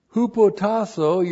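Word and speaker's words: Hupotasso (ὑποτάσσω): Verse 5, young men, in the same way, hupotasso, your pastor. Hupotasso, H-U-P-O-T-A-S-S, long O.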